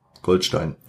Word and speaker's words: Goldstein: Ist jetzt Stein oder Steen? Mit EI, also Stein auf Deutsch. Goldstein.